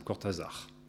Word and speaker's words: Cortázar: Pontalis, m'avait dit un jour « Comment, tu n'as jamais lu Cortázar ?» qu'il prononçait plutôt à la française, en accentuant la dernière syllabe, Cortázar.